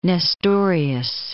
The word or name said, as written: Nestorius